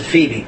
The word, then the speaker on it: Phoebe: Paul says, I commend to you our sister Phoebe, or as we can pronounce it, Phoebe. There's two different ways to pronounce it. I'll pronounce it Phoebe, which is the English way.